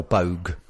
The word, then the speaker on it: Boag: Her maiden name was Snart, S-N for Nigel, A-R-T-T, which is a Scandi name. – Well, I mean, BOAG, or Bogue, as it should be pronounced, is mainly a Scottish name.